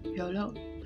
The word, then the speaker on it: Jolo: Actually, it's spelled J-O-L-O, but it's pronounced completely different, Jolo, in the Philippines.